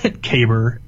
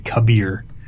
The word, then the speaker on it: Kabr: It's worth mentioning, though, in that mission, it's pronounced two different ways uh, Kaber and Kabir. Uh, the it's and it's Praedyth who pronounces it Kabir. Uh, but the true and one pronunciation is in fact Kaber.